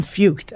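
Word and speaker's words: Feucht: You got worship leaders like, I think it's Sean Fucht, I think I said it right, holding worship services in Seattle, in Portland, where thousands of people are worshiping the Lord under the open sky.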